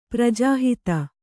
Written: ಪ್ರಜಾಹಿತ

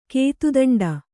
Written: ಕೇತುದಂಡ

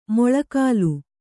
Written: ಮಳೆಗಾಲ

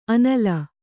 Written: ಆನಿಲ

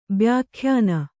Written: ಬ್ಯಾಖ್ಯಾನ

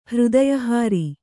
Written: ಹೃದಯಹಾರಿ

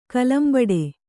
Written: ಕಲಂಬಡೆ

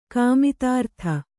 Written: ಕಾಮಿತಾರ್ಥ

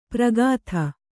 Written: ಪ್ರಗಾಥ

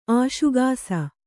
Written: ಆಶುಗಾಸ

